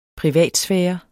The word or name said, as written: privatsfære